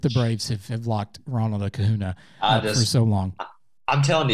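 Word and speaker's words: Acuña: And then our good friend of the pod, Ronald Acuna. Let me throw you some stats out there, because Ronald Acuna, I'm sorry, I keep pronouncing his name wrong. It's Acuna.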